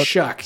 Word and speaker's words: Shough: Sure, Shuck. Yes, it? It's like, it's pronounced like S-H-U-C-K, even though it's spelled S-H-O-U-G-H, which makes no sense.